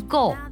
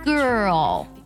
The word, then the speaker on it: girl: 不少同学呢在读这个女孩的时候 girl 的时候呢，特别人读成 girl 或者读成 go 都是错的。它这里呢有个儿化音，有个舌侧音重音 girl，要我把两个音都发清楚，这样听起来才很地道哦。